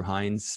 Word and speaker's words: Hines: Just type in Andrew Hines. My last name is spelled H-I-N-E-S, and you will find it.